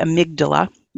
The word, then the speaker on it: amygdala: there's this whole section in your book about the amygdala, which I hope mm-hmm.